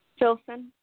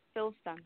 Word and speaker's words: Filson: Filson. – Jilson? – Phil, like Philip. Filson.